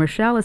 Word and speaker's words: Rochelle: My name is Rita, R-I-T-A, and Rochelle is spelled R-O-C-H-E-L-L-E.